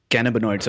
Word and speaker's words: cannabinoids: So three key things which I noted from what you said was there are over 100 different types of cannabinoids.